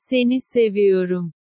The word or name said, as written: seni seviyorum